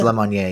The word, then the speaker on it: Lemonnier: I like how – was it Lemonnier? Is that how you pronounce it? It's Lemonnier, yeah. – It is? Yeah. Oh, and I've been pronouncing it like a white person, Lemonnier, this whole time?